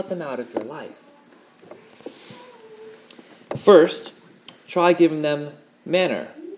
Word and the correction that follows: manure: That's the right pronunciation, right? Farmer's manner. Manure. Manure. I knew there was something wrong with that.